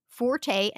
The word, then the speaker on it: forte: In British English, forte and fort predominate. Forte and forte are probably the most frequent pronunciations in American English.